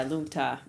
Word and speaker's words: Lung Ta: So Lung Ta is a Tibetan phrase that means wind horse. – Is that how it's pronounced? – Yeah, Lung Ta.